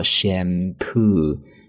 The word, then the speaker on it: shampoo: 那除了这样的一个词组之外呢，我们其他的一些相关和头发相关的一些单词啊，比如说洗发水很简单，我们叫做 shampoo，两个音节单词重音在第二个音节上，读作 shampoo。